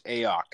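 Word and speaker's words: Aiyuk: It's Ayuk.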